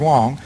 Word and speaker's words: Huang: A man named John Wong, isn't spelled H-U-A-N-G, but pronounced Wong, was given a position in the Clinton administration overseeing trade policy.